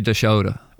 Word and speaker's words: Dashoda: Correct me on my pronunciation first. – I would say Dashoda.